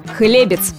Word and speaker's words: хлебец: Единственно верным вариантом признается ударение на первый слог «хлебец» и, соответственно, «хлебцев».